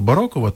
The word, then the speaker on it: барокова: Напевне, якщо Оксана Шевчук, наша режисерка, так каже, що правильно барокова, то, напевне, барокова. Бо режисери українського радіо і наголоси – це дуже серйозні люди. Тому повіримо її експертній думці і будемо далі казати барокова.